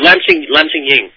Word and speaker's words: Lam Ching Ying: Lam Ching Summit. – Oh, Lam Ching Ying.